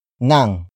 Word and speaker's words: ng: Nang is spelled as ng.